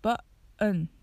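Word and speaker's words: button: この方法で発音されている単語の代表としてはボタンという意味のバンがあります。バンと発音されて t の音が消えていますね。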